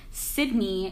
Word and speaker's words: Sydney: Yeah, and if you didn't get annoyed by me from this, you can follow me on Instagram at Sydney, which is CYDKNEE.